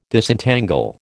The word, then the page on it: Disentangle: American English